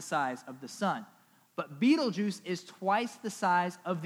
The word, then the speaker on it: Betelgeuse: This star is called Betelgeuse, or can also be pronounced Betelgeuse. So you better believe, because we're at Core Church, we're going to call it Betelgeuse.